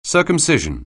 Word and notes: circumcision: anglais

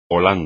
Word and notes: Holanda: espagnol

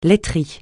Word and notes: laiterie: francais

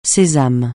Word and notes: sésame: francais

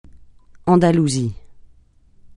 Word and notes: Andalousie: francais

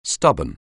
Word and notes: stubborn: anglais

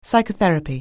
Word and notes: psychotherapy: anglais

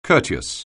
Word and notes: courteous: anglais

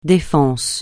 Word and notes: Défense: francais

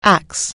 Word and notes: awry: anglais

